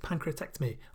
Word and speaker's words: pancreatectomy: Yeah, that's how you say it, pancreatectomy, or removal of the pancreas, and only died when there was no more extract left.